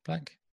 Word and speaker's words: Blacc: I think that's pronounced it. B-L-A-C-C. Black? – Yeah.